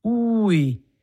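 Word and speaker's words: új: Nicht zu verwechseln mit dem Wort neu. Ui, mit einem J und einem Axo auf dem U. Das heißt, das U wird lange ausgesprochen. Ui heißt neu und nicht Finger.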